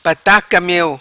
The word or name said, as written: Pataca Meo